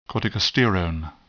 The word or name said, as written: corticosterone